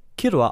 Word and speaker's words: Killua: Ein weiterer Fakt ist, der sich auf den Namen von Killua bezieht, ist, dass dieser im japanischen Original Killua ausgesprochen wird, das sich aus den zwei Begriffen Kiru, was die japanische Aussprache für das englische Verb kill ist,